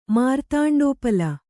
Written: ಮಾರ್ತಾಂಡೋಪಲ

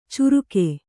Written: ಚುರುಕೆ

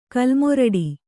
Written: ಕಲ್ಮೊರಡಿ

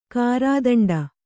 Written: ಗರ್ದುಂಡಿ

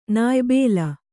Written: ನಾಯ್ಬೇಲ

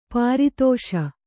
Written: ಪರದೇಶಿ

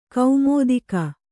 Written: ಕುಮುದಿಕೆ

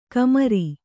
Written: ಕಮರಿ

